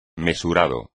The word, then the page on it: mesurado: Spanish